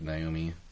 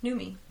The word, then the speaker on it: Noomi: Is that how you say it? – Noomi Rapace. – I thought it was like Naomi. – Noomi. – Okay, I don't know. – It's Man, N-O-O-M-I.